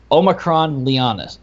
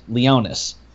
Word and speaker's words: Omicron Leonis: Leo has a minimum of 10 stars, as long as you include the star that's in his paw, which is I want to make sure I pronounce this right. It's Omicron Leonis. Leonis, there we go.